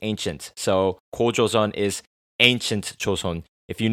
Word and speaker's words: 고조선: Also known by the English name National Foundation Day, this holiday celebrates the legendary formation of the first Korean state of Gojoseon in 2333 BC. That's right, that's 2333 BC, motherfucker. Gojoseon, my Korean pronunciation a bit off. 고조선, 고조선.